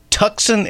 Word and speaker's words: Tucson: Well, would you imagine that Tucson is spelled with the C? – Yeah, it is weird.